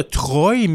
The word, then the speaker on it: Troyes: Et puis Troie s'appelle T-R-O-I-E. E. – Oui. – Tandis que là, Chevalier de Troie, c'est T-R-O-Y-E-S. Alors, on pourrait dire de Troie, mais c'est. – on le, prononce quand même Troie.